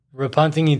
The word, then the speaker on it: Repentigny: My pronunciation, Repentigny? – Repentigny too, yeah.